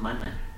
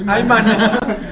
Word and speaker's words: mana: Sí, una cosa es maná y otra cosa es mana. – Bueno, igual lo pronuncié mal. – Ay, mana.